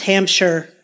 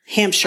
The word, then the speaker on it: Hampshire: It'd be pronounced Hampshire. Hampshire. – I was going to say, I pronounced it like somebody from Indiana. – You did. Which, you know, I've just been there. That's the only reason I know. But I used to say Shire too, and one of our English friends really corrected me.